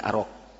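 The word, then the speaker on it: arok (أراك): Berarti bukan arak tapi arok. Ya, bacanya arok.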